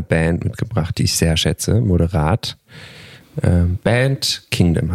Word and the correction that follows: Bad Kingdom: hast du ausgesucht. – Mhm. – Bad Kingdom, genau, ja. Bad Kingdom, habe ich komisch ausgesprochen, aber ja, genau.